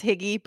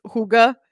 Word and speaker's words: hygge: I want to pronounce this Higgy, but it's not. It's Hygge.